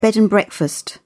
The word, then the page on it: bed and breakfast: British English